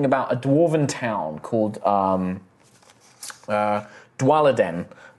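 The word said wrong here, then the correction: Dwalinden: Dwalenden, sorry, Dwalenden. – Could you spell that for me? – D-W-A-L. – A-L-I-N. I-N. – D-E-N.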